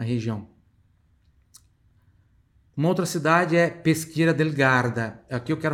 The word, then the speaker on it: Peschiera: Depois tem Peschiera del Garda, que é a cidade. Aqui tem uma pronúncia interessante, ó. O CHI tem som de qui. Chega aí, tem som de qui. Repita em voz alta. Peschiera del Garda.